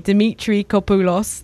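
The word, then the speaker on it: Dimitrakopoulos: That beautiful emotional track was Aware, L-O-R. That was following Deeper Than Skin from Blend Mishkin and Panos Dimitri Apokolos. Hard to pronounce that one for me. Dimitri Kopoulos, that's how you say it.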